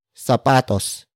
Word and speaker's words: sapatos: It is an object marker that introduces what will be purchased, and it will be sapatos.